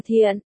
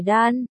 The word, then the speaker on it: Thiện: Gian đọc là Thiện, không phải Đan, 30 tuổi, không phải người Viễn Thành.